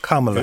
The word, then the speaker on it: Kamala: She's Kamala Harris. – So I got an email from somebody saying I mispronounced it earlier. Kamala.